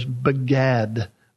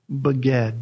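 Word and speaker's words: begad (בגד): If we spell it in English letters, begad, begad, B-E-G-A-D. It's related to the word beged, B-E-G-E-D. B-A-G-A-D, B-E-G-E-D.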